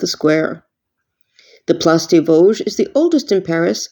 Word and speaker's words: Vosges: Think of the word loge like a theater seat, but with the letter V in front, Vosges.